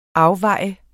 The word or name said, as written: afvej